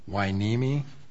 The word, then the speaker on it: Wainimi: And just for the record, is it pronounced Wainimi? – Yes, it is a Wainimi.